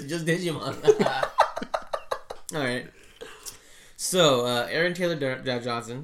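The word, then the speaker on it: Djimon: Actually, I think it is Digimon. – That's how you say it. – Digimon Hansu. – At first, like, people would say his name is Digimon. I'm like, damn, that's real fucking disrespectful. But then it's like, it actually is Digimon.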